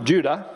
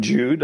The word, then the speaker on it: Jude: Now, in different languages, it can be pronounced Judah, but I'm doing Jude.